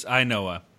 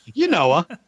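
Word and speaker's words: Ynoa: It's probably Huascar Noah. – No, what do you think? – It's Ainoa. He's got a brother, Michael Ainoa, who pitches for the White Sox. And so I was able to find his last name.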